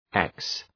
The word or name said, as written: ex-